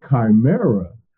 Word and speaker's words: chimera: So a chimera is how it's pronounced. C-H-I-M-E-R-A-S.